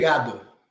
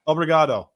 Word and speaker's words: Obrigado: How do you say thank you in Brazilian? In Portuguese? – Obrigado. – Obrigado. That's right.